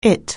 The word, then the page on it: it: anglais